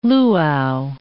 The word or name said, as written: luau